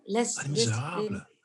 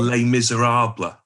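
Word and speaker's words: Les Misérables: Les Miserables. It's your accurate French pronunciation. It's what we English call Les Miserables.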